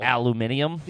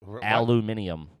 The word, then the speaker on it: aluminium: Aluminium? – Yeah. We're not scientists. – That's how the British say it. Aluminium.